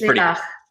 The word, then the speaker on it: Lilach: It's pretty close. It's Lilach, but Lilach. That'll do. Honestly, don't worry about it.